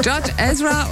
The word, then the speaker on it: George Ezra: Jord, ich weiß nicht, ob ich das genau ausspreche oder richtig ausspreche. Jord Ezra. – Ja. – Und Shotgun. – So ist es. George Ezra und Shotgun ist richtig.